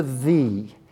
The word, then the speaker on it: the: By the way, it's not a Y. It's not ye. There's no such word. There's no such article in English. It's an old letter called a thorn that is pronounced th.